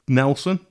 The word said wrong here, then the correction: Delson: Yeah, Delson, yeah.